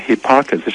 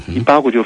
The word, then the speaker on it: Hipparchus: 以前我们有过一次叫做伊巴谷啊，那个英文字念成 Hipparchus 是美国人的念法，欧洲人的念法是伊巴谷，伊巴谷就翻成伊巴谷了。